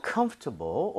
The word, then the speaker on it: comfortable: 근데 이거 발음할 때 있잖아요. 원어민들이 comfortable, comfortable 이렇게 부드럽게 하는 사람들 있는데요. – 네. – 오히려 이 단어는요, 이런 발음을 들을 확률이 높아요. 뭐냐면 comfortable, comfortable. 뭐냐면 여러분들 중간에 OR 보이죠? – 네. – 이거를 발음을 거의 안 하는 사람도 엄청 많습니다.